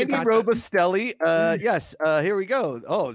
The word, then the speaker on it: Robustelli: Robusticelli. I think I'm saying it right. Robusticelli. You know the Andy Robusticelli. I think he was a Hall of Famer. – Robustelli? Robustelli. – Robustelli. Yeah, he got it.